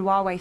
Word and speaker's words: Huawei: So Google have said they're no longer going to let people use Google apps on Huawei phones. – Cancelled. – Huawei. – Is that how you pronounce it? – Yeah. – Huawei.